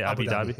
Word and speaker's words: Abu Dhabi: the fight this Saturday was in Abu Dhabi. Is that how you say it? Abu Dhabi? – Yeah.